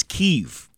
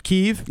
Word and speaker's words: Kyiv: It's Kyiv. – Is it Kyiv? – Yeah. – Oh, somebody wrote in and said, will you tell this douchebag to stop pronouncing it Kyiv? It's Kyiv.